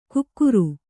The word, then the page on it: ಕುಕ್ಕುರು: kukkuru